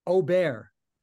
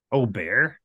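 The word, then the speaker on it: Åberg: It's Ober. That's how you say it. – Ober.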